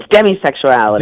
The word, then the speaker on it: demisexuality: So did I say it right? Demisexuality or demi? What is it? – I think it's demisexuality. – Demisexuality.